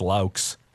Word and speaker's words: Loucks: People still can't pronounce my name after 4 years. Well, good morning, Mr. Loucks. It's Loucks.